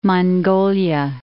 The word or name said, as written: Inner Mongolia